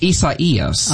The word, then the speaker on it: Isaias: Actually, it's pronounced Isaias. – Oh, thank you for correcting me. – Isaias, yes. That is the official pronunciation from the Hurricane Center.